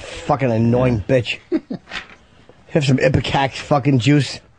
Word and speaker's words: ipecac: How do you say that word? I-P-E-C-A-C? I can never say that word. – Ipecac. – All right, Ipecac.